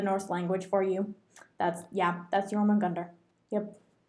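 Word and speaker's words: Jörmungandr: But Jormungandr, it's J and O with those two little dots over it. – Oh, umlau. – Yeah. J, O with two dots.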